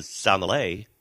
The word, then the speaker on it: sommelier: They're going to be hanging out, and it's curated by professional chef who's been on the show here and sommelier.